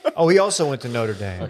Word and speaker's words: Notre Dame: They were brothers, played at Notre Dame, which is probably why Golic Jr. went to Notre Dame. It's pronounced Notre Dame.